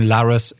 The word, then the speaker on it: Larres: Yeah, it's my surname, my last name, Laris at unc.edu, and Laris is spelled L-A-R-R-E-S.